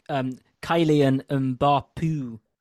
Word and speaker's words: Kylian Mbappé: It's just everyone keep an eye out on he's called Kylian Mbappé Lota. So just keep an eye out. – I think it's pronounced Kylian Mbappé. – It's my mistake.